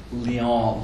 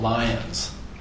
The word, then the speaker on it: Lyon: And he insisted on calling Marseille, Marseilles, and Lyon, Lyons, because that's how upper-class Brits would pronounce those places.